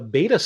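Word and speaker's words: b8ta: I tried it on demo at a beta store. It's spelled B, the number 8 T A, in Palo Alto.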